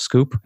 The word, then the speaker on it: SKWP: My handle is SKWP, which is pronounced Scoop, SKWP.